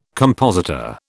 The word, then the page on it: compositor: British English